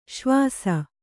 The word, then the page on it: ಶ್ವಾಸ: śvāsa